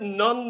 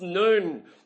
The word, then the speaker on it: nun: But the word nun there is actually pronounced noon. Noon, as in high noon.